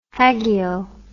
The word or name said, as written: hagio